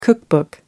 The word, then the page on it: cookbook: British English